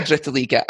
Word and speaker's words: Dritte Liga: I was going to say Dritte Liga,